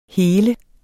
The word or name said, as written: hele